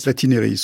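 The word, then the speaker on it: Platynereis: Une forme ancestrale de cerveau avec l'exemple d'un petit ver marin qui a 600 millions d'années, le ver Platineris. Je ne suis pas tout à fait sûr de sa prononciation. – Platineris, oui.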